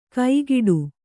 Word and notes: ಕೈಗಿಡು: kaigiḍu

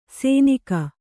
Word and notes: ಸೆನಿಗ: seniga

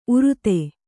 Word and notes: ಉರ್ದು: urdu

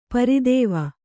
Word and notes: ಪರಿದೇವ: pari dēva

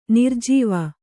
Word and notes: ನರಜೀವ: Kannada; nara jīva

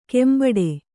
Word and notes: ಕೆಂಬಟ್ಟೆ: kannada

